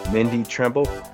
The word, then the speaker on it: Mindi Trimble: And I like to say it's like thimble with an R, but some people do say Trimble.